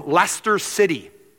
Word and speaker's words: Leicester: There was a team from Leicester City. It doesn't look like Leicester City, but that's how you say it, right, Paul?